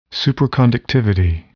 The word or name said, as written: superconductivity